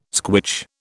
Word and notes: squitch: British English

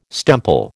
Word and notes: stemple: American English

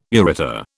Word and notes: ureter: British English

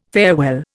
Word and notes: farewell: Indian

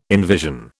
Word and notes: envision: British English

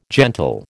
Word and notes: gentle: American English

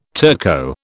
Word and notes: Turco: British English

